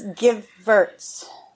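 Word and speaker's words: Gevirtz: is it Gervitz? – I just really learned how to say it. – Oh, hi. – It's Givertz. – Givertz, like you're going to give something. Givertz.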